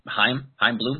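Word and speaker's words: Chaim Bloom: My scared skepticism here with what's going on is that it's going to be a bridge year because of Heim Bloom. Is that how you say it? – Yep.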